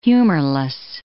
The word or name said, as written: humorless